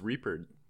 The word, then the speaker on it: Reapered: Is it Reappeared? – It's Reapered.